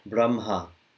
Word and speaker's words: Brahma: as if the H was after the M. – Yeah. – Brahma. – Brahma. – It's the only time I'm aware of where it's pronounced not the way it's written.